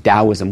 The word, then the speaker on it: Taoism: It's always pronounced Taoism with a D, but there were some linguistic reasons why they used the T earlier.